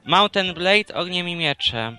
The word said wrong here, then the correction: Mount and Blade: A Mount and Blade.